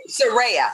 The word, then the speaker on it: Serayah: I want to say her name, Serayah. Correct? That's correct. Oh, Serayah. – Serayah. Uh-huh, Serayah. Serayah. Yes, uh-huh.